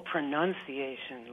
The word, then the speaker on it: pronunciation: Oh, pronunciation. Like nuns. – I'd say pronunciation. – The nun, with a nun in there? – Yeah.